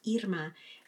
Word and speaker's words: Irma: My name is pronounced Irma. And she said, oh, well, I don't think I can say it. I can't roll my R's.